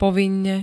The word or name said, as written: povinne